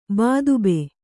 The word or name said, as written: ಬಾದುಬ್ಬೆ